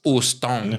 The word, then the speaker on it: Houston: H is silent. So Houston.